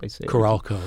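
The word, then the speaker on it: Carolco: Okay, and then after Sam Raimi, well, September 1994, Carelco Pictures, is that how you say it? – Carelco, yeah.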